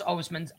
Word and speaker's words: Osman: How do you pronounce Osman's? Osman. – Chetty Osman. Yeah.